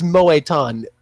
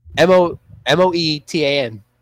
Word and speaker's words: Moetan: I have a feeling there's an accent It's Moe-tan. How you think you would spell it, it's like just all one word. M-O-E-T-A-N.